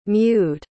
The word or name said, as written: mewed